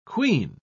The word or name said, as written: quean